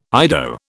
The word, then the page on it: Ido: British English